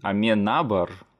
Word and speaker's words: Аменабар: Да, я буду его произносить Алехандро Аминябар. – Мне кажется, ударение ты все равно продолжаешь ставить не туда. Алехандро Аминябар, нет? Нет, у него не на последнюю, а на предпоследнюю А ударение. Аменабар?